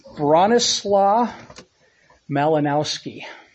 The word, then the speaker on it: Bronislaw Malinowski: I pronounced this earlier and I nailed it. Bronislaw Malinowski. All right.